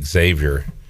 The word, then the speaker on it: Xavier: You don't pronounce the X in Xavier, and I struggle with that sometimes. – Xavier. – It's just Xavier.